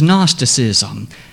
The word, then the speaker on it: Gnosticism: It is known as Gnosticism,